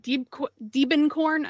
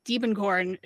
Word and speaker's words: Diebenkorn: In 1993, we lost painter Richard Diebenkorn. I always say it wrong. Diebenkorn, who was 70.